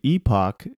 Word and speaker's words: epoch: I've always thought that E-P-O-C-H, which is a large amount of time, you know, was pronounced Epoch. – According to Google... – Epic. Epic. Epic. – All right. Well, Google's never wrong. – I disagree. – The Epic Times, the Epoch Times, an epoch's on your house. – Here we go. – You can say it, but you have to know that the British say it differently as epoch. Epoch or epoch. Both pronunciations are correct, just different ways of saying it.